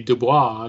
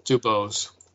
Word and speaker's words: DuBose: It could be Dubois. I don't know. Well, I don't know. It's not spelled that way. It's not spelled like Dubois. Yeah. – Yes. – Dubose.